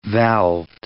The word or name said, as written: valved